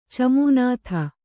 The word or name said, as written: ಚಮೂನಾಥ